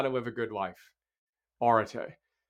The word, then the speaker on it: arete: So arete is how you pronounce the book's title.